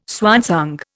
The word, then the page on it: swansong: Indian